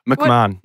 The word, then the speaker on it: McMahon: McMahon.